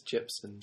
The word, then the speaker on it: Jepsen: But Carly Rae Jepsen did have the... have I said the name wrong? – I think it's Jepsen.